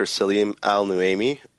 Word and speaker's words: Saleem Al-Nuaimi: So my name is Dr. Saleem Al-Nuaimi, but everyone calls me Dr. A.